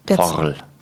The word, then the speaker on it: FORL: Ich sage jetzt mal in Anführungsstrichen Katzenkaries, das ist keine Karies, das ist im Fachjargon nennt man das Forl. Ich weiß nicht, ob du das schon mal gehört hast. – Nee. – F-O-R-L. – Forl.